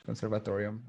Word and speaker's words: Conservatorium: Then I joined the Conservatoire, which is the music school in, how do you say this in English? Conservatorium, I don't know. – Yeah, you're saying it right.